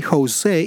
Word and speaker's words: Jose: Jose is J-O-S-E because I am Latino, so that's why my pronunciation is a bit stronger.